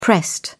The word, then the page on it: pressed: British English